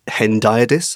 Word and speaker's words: hendiadys: Ever since I read 1599 and you alerted me to this literary concept of hendiadys, am I saying that right? – Yes, you are.